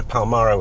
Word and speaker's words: Palmyra: And my dad was a sort of amateur archaeologist, and we'd go to Damascus, and then we'd go to Palmyra, which is my favorite place on earth. – I'm loving your correct pronunciation, because I would have probably said Palmyra. – Everyone says Palmyra.